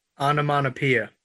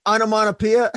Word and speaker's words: onomatopoeia: And then we got how do you pronounce it? Somebody help me out. – Onomatopoeia. – Onomatopoeia. Thank you.